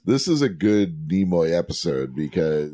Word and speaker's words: Nimoy: Well, look at the amount of makeup on Nimoy. Is that how you pronounce it? – Nimoy!